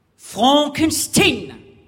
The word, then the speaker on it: Frankenstein: Frankenstein. Mon nom se prononce Frankenstein.